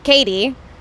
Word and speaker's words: Caite: Well, because so many people spell my name wrong, even whenever they see it, when they Google me, is spelled correctly, C-A-I-T-E, Katie Upton, U-P-T-O-N.